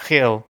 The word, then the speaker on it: Gil: It's, I couldn't tell how to pronounce it, but the way it was described on the Wikipedia page was like the CH in lock. So it's like Hill.